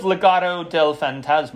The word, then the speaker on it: Legado del Fantasma: Well, what do you think of Legado del Fantasma? – Oh, did I say that right? – Yes.